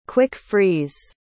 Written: quick-freeze

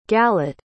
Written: galit

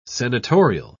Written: senatorial

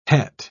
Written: het

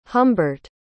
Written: humbert

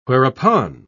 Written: whereupon

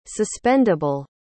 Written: suspendable

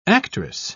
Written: actress

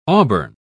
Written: auburn

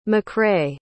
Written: MacRae

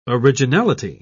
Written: originality

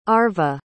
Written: Arva

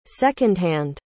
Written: second-hand

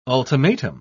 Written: ultimatum

